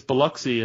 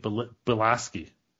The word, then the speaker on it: Biloxi: It's Biloxi, and they're pronouncing it Bilaski.